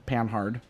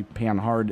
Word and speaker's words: Panhard: Which Pa is actually Panhard. It's spelled Panhard, but because French is a stupid language, every letter after the second letter is silent. – It'd be like Panard, probably, in French.